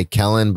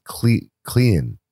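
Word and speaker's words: Clelin: His name is always hard for me to pronounce because I always want to say Kellen, but it's Cleland.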